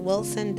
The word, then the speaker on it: Wilson: Mika is spelled M-E-K-A. Last name is Wilson, W-I-L-S-O-N.